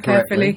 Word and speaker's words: Caerphilly: Caerphilly Castle? – No, you spelled that right. Pronounced that right. Caerphilly, That's...